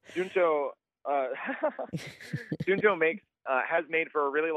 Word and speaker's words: Suunto: This wearable fitness tracker from, how is it pronounced, Suunto? – Yeah, Suunto.